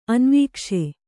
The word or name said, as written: ಅನ್ವೀಕ್ಷೆ